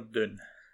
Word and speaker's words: Dune: Is it Dune or Dune? – I know. I feel Scottish people have to pronounce it as Dune. D-O-O-N. Dune.